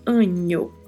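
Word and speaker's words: año: Angel, que é pronunciado com esse som de NH, tá? Quando encontra-se a letra N com o tio em cima, pronunciamos sempre com o som do NH do português. Então falamos Anho.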